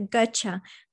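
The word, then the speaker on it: Götzsche: Peter Gotcher. It's like Gotcher.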